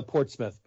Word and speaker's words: Portsmouth: Oh, it's uh, Portsmouth, but that's fine.